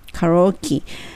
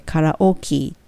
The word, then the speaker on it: karaoke: yesterday で、 but I ところをくっつけると but I なので but の t が l に変わって but I went to karaoke カラオケえっと英語の発音では KE はキーになるのでカラオキーっていう風になります。